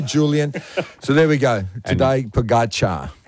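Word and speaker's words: Tadej Pogačar: Tadej, you can pretty much consider the J in his first name as an I. Tadej Pogacar. – Pogacar. – You can consider the C with the symbol on it as to be pronounced like a CH. Pogacar. – Pogacar. – You do want to stress on the second syllable of his name.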